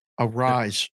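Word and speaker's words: Arráez: Arise. That's how you say it. Arise.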